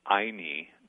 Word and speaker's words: ayni: In a chapter of your book called Abraham's Sandwich, you mentioned the word Anyi, or Anyi. I'm not sure how it's pronounced. What is Anyi? – It's actually pronounced Aini. The word is spelled A-Y-N-I.